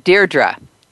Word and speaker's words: Deirdre: Deirdre.